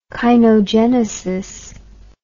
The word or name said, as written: kainogenesis